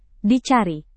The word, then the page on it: dicari: Indonesian